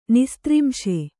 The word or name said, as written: ನಿಸ್ತ್ರಿಂಶ